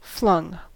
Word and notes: flung: American English

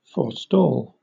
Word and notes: forestall: British English

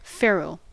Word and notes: ferrule: American English

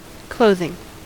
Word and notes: clothing: American English

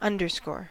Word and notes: underscore: American English

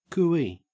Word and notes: cooee: Australian English

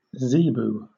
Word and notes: zebu: British English

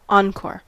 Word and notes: encore: American English

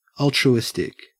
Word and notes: altruistic: Australian English

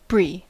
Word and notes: brie: American English

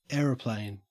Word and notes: aeroplane: Australian English

